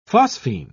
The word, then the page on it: phosphine: British English